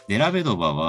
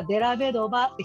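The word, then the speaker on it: Dellavedova: デラベドバは、最後のドウのところがアクセントってことかな、そうです。そこ、そこをアクセントにしたした方が言いやすいから、デラベドバってひら、なんか、平たフラットに言うよりも、デラベドーバーって言った方が言いやすい。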